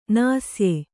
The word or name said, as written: ನಾಸ್ಯೆ